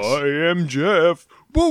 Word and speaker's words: Gef: G-E-F, but it's pronounced Jeff.